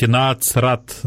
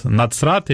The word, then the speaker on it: Нацрат: Кстати, евреи меня поправляли, говорили, что правильно говорить «нацрат», «нацрат» или «нацарет». Вот. Нету такого понятия, как «з», вот эта вот буква, это европеизированная, да?